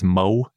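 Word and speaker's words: Möwe: It's called I think it's Mowe is how it's pronounced, M-O-W-E with an umlaut over the O.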